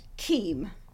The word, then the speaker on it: Kiem: It's a German name that travelled to Australia and got all confused. So, in Germany, you would pronounce it Kiem, but you know, the other side of the world, you spin things around a bit. It's Kiem.